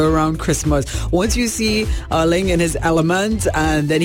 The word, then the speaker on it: Erling: And, or should we say in his language, Erling. I'm really making an effort to remember how to pronounce it. Erling Haaland.